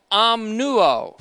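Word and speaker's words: omnuo (ὀμνύω): It's an aorist, active, first-person singular form of the verb omnuo, for swearing an oath, O-M-N-U, long O.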